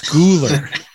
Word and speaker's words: Schooler: It's not like a German pronunciation. It's Schooler.